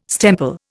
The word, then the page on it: stemple: Indian